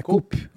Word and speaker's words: Coup: Como é que fala? Coop? É Coop. Coop. Coop. – É. Se escreve C-O-U-P.